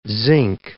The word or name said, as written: zinc